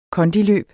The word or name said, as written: kondi